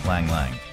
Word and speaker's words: Lang Lang: Pronounce Lang Lang.